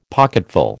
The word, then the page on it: pocketful: American English